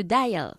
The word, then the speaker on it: dial: To deal. – ¿Y cómo se pronuncia? – To dial. – Eso es, to dial, vale.